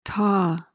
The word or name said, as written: taw